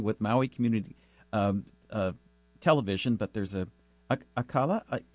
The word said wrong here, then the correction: Akaku: is it? Let me make sure. – Akaku, Maui Community.